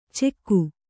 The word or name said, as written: ಚೆಕ್ಕು